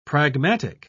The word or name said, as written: pragmatic